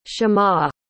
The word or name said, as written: shema